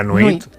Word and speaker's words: nuit: La nuit. – La nuit. – Nuit, muy bien. O sea, huit, nuit. Perfecto.